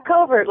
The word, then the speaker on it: Covert: Covert, like covert op.